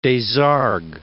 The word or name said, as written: Desargues